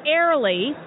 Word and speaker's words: Airlie: I am the senior sales manager at this beautiful, beautiful property in Warrenton, Virginia, called Airlie. It's spelled A-I-R-L-I-E, just like the air you breathe.